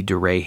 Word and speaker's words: Duret: Duray hemorrhage. It looks like it's spelled Durette, capital D-U-R-E-T, Duray hemorrhage.